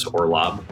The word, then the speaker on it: Orlob: My name's Chris Orlob. So last name is spelled O-R-L-O-B as in boy.